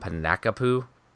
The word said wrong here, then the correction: Pankapu: Oh, I call it Pankapu. – Pain, P-A... – A... – N-K-A-P-U.